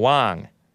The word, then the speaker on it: Wang: Wang, or Wang, W-A-N-G. Sometimes that's pronounced Wang, believe it or not, even though that's also W-O-N-G.